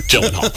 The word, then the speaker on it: Gyllenhaal: And I said, Gyllenhaal. – It's a soft G.